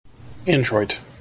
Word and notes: introit: American English

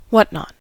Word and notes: whatnot: American English

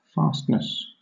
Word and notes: fastness: British English

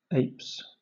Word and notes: apes: British English

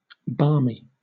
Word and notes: barmy: British English

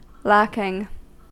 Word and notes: lacking: American English

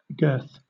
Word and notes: girth: British English

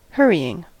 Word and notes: hurrying: American English

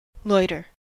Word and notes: loiter: American English

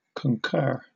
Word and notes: concur: British English

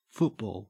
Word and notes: football: Australian English

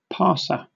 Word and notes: parser: British English

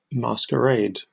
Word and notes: masquerade: British English

